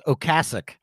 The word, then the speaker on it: Ocasek: His name is actually pronounced Ocasek. – Seriously? – I didn't know that. – Yeah. On the Rock and Roll Hall of Fame induction, he said 99% of people call him Ocasek, but it's Ocasek.